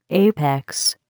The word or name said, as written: apex